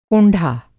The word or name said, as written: कोंढ़ा